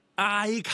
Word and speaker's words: 埃及: 咁讀埃及咧，原因係好簡單嘅啫，因為我哋中文寫出嚟土字邊嗰個埃字咧，喺粵語入邊本身係得一個讀音嘅啫，就係塵埃個埃。咁但係點解我話埃及係應該讀埃及咧？